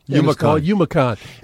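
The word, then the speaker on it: YouMaCon: No, it's going on this weekend, and it's called UmaCon. – I'm never going to get that right. – Oh, yeah. Well, just look at it just the way it's spelled. It's Y-O-U-M-A-C-O-N. – Okay. – UmaCon.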